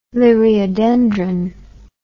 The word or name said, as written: liriodendron